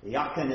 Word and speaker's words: Jachin: Jachin or Jachin. Jachin is the pronunciation in Hebrew.